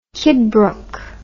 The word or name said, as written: kidbrooke